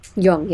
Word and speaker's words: Jung: And you said it's pronounced Jung? – Jung, yeah.